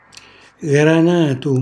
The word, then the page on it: granatu: Calabrese